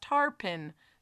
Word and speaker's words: tarpon: But Mountains Rule 1896, tarpon is usually pronounced tarpin.